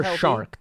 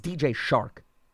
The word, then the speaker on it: Chark: DJ Chark, who also had an injury-riddled season this year. – Or Shark, too. He's the most disrespected individual. It's DJ Shark. They have butchered his last name.